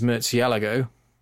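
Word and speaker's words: Murciélago: Freddie's in his DBS and Ed's in his Murcielago.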